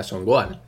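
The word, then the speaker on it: Son Gohan: aún y entenderíamos que fuera Songoanda, o sea, Songoan. Esto, ok, vale, pues te, saltas la H, no la pronuncias y ya está, pues Songoan.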